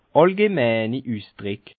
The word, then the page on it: àllgeneini Üsdrick: Bas Rhin; Schiltigheim